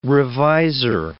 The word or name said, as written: revisor